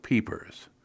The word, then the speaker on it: peepers: For me, the most wonderful sign of spring is the sound of peepers, little frogs, as you know. Pronounced peepers, P-E-P-E-H-S, here in New England, even by those who ordinarily pronounce their R's.